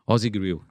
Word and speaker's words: Aussie Grill: Abrácio e Ausen Grill. Falei certo? – Aussie Grill. – Aussie Grill, perfeito.